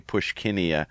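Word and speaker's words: Puschkinia: And then I'm so excited that you ID'd that one plant for me, Pushkinia, if I'm saying that right.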